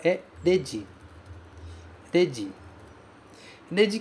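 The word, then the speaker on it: Dedi (レジ): Dedi, novamente, começa com R-E, mas é pronunciado de.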